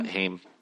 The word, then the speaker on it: Haim: Yeah, Haim.